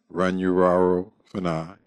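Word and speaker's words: Runyararo Fana: which is under the name of Runyuraro Fana, and that's spelled R-U-N-Y-A-R-A-R-O Fana, F-A-N-A.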